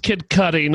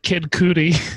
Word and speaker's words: Kid Cudi: It's pronounced Kid Cudi, not Kid Cootie.